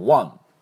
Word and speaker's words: one: で、勝ち取ったっていうことになると win の過去形の won、 won、これ won なんですけども、この o はあの音なんです。あの up とかとあ、ね、びっくりした時喉の奥であで出すやつですね、まあ、発音記号でいうととんがり帽子みたいなあですか。だから one one なんです。これ one ではなくて one ね。